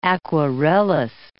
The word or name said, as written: aquarellist